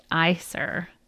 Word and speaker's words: Æsir: I think I said Æsir, so it's pronounced Æsir.